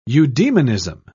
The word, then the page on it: eudemonism: British English